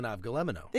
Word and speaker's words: avgolemono: This is an avgolemono.